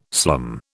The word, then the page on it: slum: British English